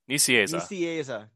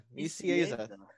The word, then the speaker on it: Nicieza: So uh, in the YouTube chat, Roland has given us uh, a pronunciation guide using the Webster's Common Format. Yeah, Nicieza. – Nicieza. – Nicieza.